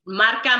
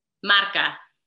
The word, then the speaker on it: Marca: Marca Marketing Consulting. – Marca. Wait, say it again. – Marca.